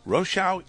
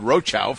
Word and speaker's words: Rochow: And yes, Rochow is the way to pronounce that name, even though you've accepted Rochow for a long time.